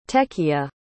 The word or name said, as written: techiya